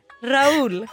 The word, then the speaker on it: Rahul: R-A-U-L. Raul. – Totalt slaktar är ju min stackars landsmans namn. Det uttalas Raul och stavas R-A-H-U-L.